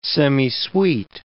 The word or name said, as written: semisweet